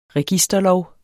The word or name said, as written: registerlov